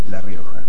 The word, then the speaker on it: La Rioja: Además está decir que la capital de la provincia lleva su mismo nombre, la ciudad de La Rioja.